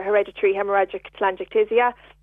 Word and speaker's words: hereditary hemorrhagic telangiectasia: Okay, so basically HHT stands for hereditary hemorrhagic telangiectasia, and what that is really is a rare genetic disorder that affects the blood vessels.